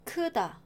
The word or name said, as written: 크다